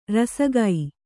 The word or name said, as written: ರಾಸಿಗೈ